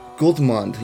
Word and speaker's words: Gudmund: Yeah, and it's it's a Dutch name, so usually you would say, Gudmund, but okay, you pronounced it the right way in English, I guess so.